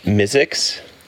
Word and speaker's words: MI6: It's actually pronounced Mizzix. – Mizzix? – Yes. – MI6 is called Mizzix. – Mizzix. – Okay. That's sorry. – That's how the true Brit says it.